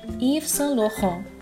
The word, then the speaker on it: Yves Saint Laurent: 由于是法语词，在读这个品牌名的时候要注意它的读音是法语语音，读成 Yves Saint Laurent。字母 R 是法语中独特的小舌音，听起来是不是比较像我们吐痰前唠痰的声音呢？